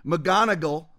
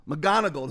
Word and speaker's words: McGonigal: McGonigal. McGonigal.